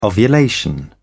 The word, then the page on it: ovulation: British English